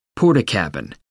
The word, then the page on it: portakabin: American English